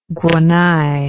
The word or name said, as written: guanay